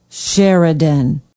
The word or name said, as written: Sheridan